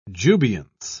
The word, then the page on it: jubilance: British English